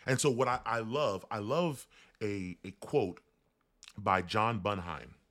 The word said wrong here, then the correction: Bunyan: who says this. I'm sorry, I'm butchering his name. John Bunyan, that's his name.